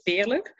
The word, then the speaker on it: Veerle: My name is Veerle. It's a Dutch name, so it's a bit hard to pronounce.